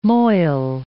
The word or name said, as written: moil